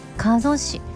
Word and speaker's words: 加須市: 東京タワーの鯉のぼりや埼玉県、これ加須市って読むんでしょうね、加須市、加えるに、すと書いてぞって読む、加須市の、 100 メートルの鯉のぼりは見てみたいですねって。